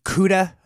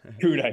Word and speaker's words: CUDA: I know it's kind of a mouthful, but is that pronounced CUDA? – Yeah, that's right, CUDA.